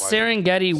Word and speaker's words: Serengeti: That's Serengeti. – Serengeti. I couldn't tell if that was an I at the end. I wanted to say Sergeant. I knew that was wrong.